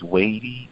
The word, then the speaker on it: Waddie: So throughout life, when people first pronounce my name as Waddie, I do get happy. Oh, wow, you got it right.